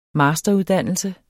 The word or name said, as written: masteruddannelse